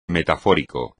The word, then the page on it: metafórico: Spanish